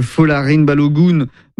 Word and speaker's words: Folarin Balogun: Donald Trump qui appelle la FIFA pour lever un carton rouge qui visait le meilleur buteur de l'équipe américaine, Folarin Balogun.